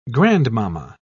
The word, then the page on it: grandmamma: British English